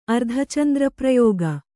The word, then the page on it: ಅರ್ಧಚಂದ್ರಪ್ರಯೋಗ: Kannada; ardhacandraprayōga